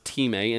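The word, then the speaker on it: time (τιμή): Now, the next word I want us to look at is this word, it looks like time, but it's pronounced time in the original Greek, and this is where we draw this word honor from within the pages of Scripture.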